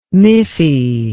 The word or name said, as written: miffy